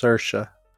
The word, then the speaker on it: Saoirse: And and by the way, her name is pronounced Sersha.